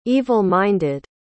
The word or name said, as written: evil-minded